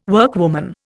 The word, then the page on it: workwoman: Indian